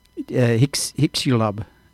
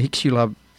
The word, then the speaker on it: Chicxulub: Now, and this is the, he also gives the name of this impact, and I'm not sure how to pronounce it, but it's spelt C-H-I-C-X-U-L-U-B. It's something like Hixulub.